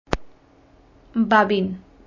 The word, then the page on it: Babin: Spanish